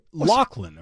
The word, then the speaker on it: Loughlin: By the way, Loughlin, apparently. I've been saying it wrong my whole life as well. It's Lori Loughlin.